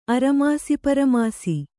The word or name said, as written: ಅರಮಾಸಿಪರಮಾಸಿ